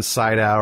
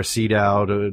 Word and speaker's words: Sydow: Max von Sydow, or Sydow, depending on your pronunciation, as Ming the Merciless, which is one of the great performances.